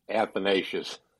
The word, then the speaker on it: Athanasius: And by the way, that's how you say it, Arv. Athanasius.